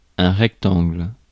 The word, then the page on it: rectangle: [ʁɛk.tɑ̃ɡl]; France